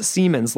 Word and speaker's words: Seaman: It is pronounced Siemens, like the engineering firm.